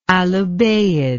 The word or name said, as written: alubayyid